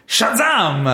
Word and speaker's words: Shazam!: Questa settimana è il turno di Shazam!